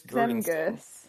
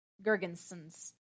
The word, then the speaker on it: Girgensons: Zemgus Girgensson. Yep. – Girgensons.